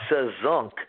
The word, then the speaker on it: Sazonk: Well, let me first of all straighten that one out. It's Sazonk, which is actually a combination of three musical terms, salsa, jazz, and funk, hence the word Sazonk.